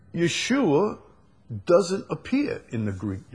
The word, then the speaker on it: Yeshua: He's Yeshua. You use the Hebrew pronunciation. Now, I don't have a problem with, you know, Yeshua.